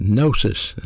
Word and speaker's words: gnosis: The first Greek word is pronounced gnosis and spelled G-N-O-S-I-S, and we get an English word from that called knowledge.